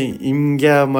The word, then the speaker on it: イムギャー: だけど初めて聞いたときにえって言って、インゲンのインにギャップのギャですって言われて、えで、でもインじゃないんですよね、インゲンのインじゃないんですよ、イムなんですよ、イムギャーマリンビーチなんですけど、イムギャーを普通にナチュラルに発音してインギャーマリンガーデンっていう地名なんですけど、もうねすごい難易度高い地名だなと思い、これここでサップ体験するときに皆さんに多分ね参加者に電話して伝えると思うんですけど、毎回絶対聞き取れないと思いません？